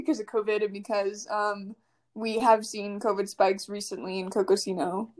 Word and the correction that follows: Coconino: Or did I say that right? Oh, dang it. I would say Cocosino and I mean Coconino.